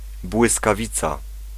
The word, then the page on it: éclair: /e.klɛʁ/; France